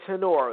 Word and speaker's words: Tenor: Tenor. That's T-E-N-O-R with the silent E at the end.